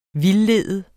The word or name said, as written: vildlede